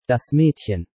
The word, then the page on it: Das Maedchen: Deutsch